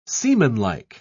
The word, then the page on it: seamanlike: British English